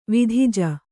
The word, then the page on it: ವಿಧಿಜ: vidhija; Kannada